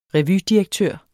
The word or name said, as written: revydirektør